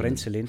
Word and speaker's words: Frenzelin: Die haben mit dem, einer von den ersten Folgen war ja mit dem ähm, Andreas Fritzelian, Herrgott, wie spricht man das? Frenzelin. Frenzelin, danke. Vom, ja, ja.